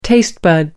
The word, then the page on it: taste bud: British English